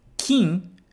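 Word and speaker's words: kin (金): Então, só toma bastante cuidado na hora de pronunciar e de escrever, principalmente se for em hiragana, ok? Então, gin é prata, kin é ouro.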